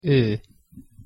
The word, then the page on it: o: Hungarian; hosszu ekezetes